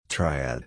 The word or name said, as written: triad